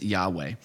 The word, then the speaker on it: Yahweh: Long story short, Jehovah's not right. The way you pronounce God's name is Yahweh.